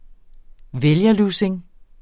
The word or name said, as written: vælgerlussing